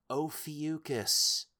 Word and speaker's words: Ophiuchus: I always pronounce it Ophicius, but I can't... I know that's wrong, and I just say it because it's funny. – Why do you guys do this shit to me? Post-editing again. The ghost's name is pronounced Ophiuchus. You uncultured chuckle fox.